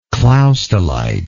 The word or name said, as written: clausthalite